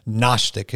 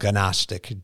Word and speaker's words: Gnostic: And just talking about the Church of Colossae, a little while ago, Kevin mentioned the term Gnostic. It's spelled Gnostic, G-N.